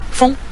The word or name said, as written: feng5